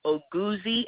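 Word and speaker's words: Oguzie: Last name is Oguzi, and it's spelled O-G-U-Z for zebra, I-E.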